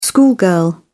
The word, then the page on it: schoolgirl: British English